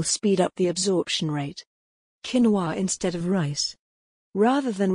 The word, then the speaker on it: quinoa: Quinoa, pronounced quinoa, tastes very similar to brown rice and has a similar look and consistency as well.